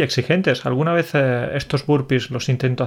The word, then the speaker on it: burpees: Burpees, creo que es uno, pero no sé cómo se pronuncia. Sí, decimos burpees, decimos, sí.